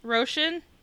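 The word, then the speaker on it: Róisín: Roshan? – Yeah.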